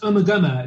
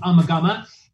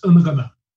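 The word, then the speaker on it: Ummagumma: I think it's called Uma Guma. Is that the name of the album, maybe? – It's actually pronounced Uma Guma. It's one of those ones that everyone mispronounces. People think it's called Uma Guma, but it's pronounced Uma Guma.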